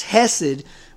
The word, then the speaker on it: hesed: The word is spelled H-E-S-E-D, hesed.